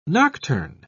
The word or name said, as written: nocturne